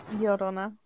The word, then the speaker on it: Llorona: Llorona. It's like the double L is like a Y sound.